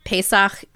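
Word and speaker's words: Pesach (פסח): So, Passover or Pesach is how you say it in Hebrew.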